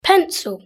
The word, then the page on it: pencil: British English